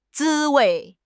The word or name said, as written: 滋味